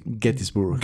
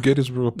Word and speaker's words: Gettysburg: se pronuncia así. Gettysburg. – Gettysburg. Puedes decir Gettysburg.